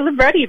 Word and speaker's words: libretti: And she as well writes, I'm going to hope I say this right, opera libretto? Is that how you say it, Maria? – Opera libretti, that's right.